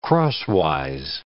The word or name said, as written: crosswise